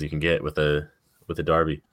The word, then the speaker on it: Derby: That Old Firm derby out there in Scotland. – It's pronounced Derby, but I'm gonna call it Derby.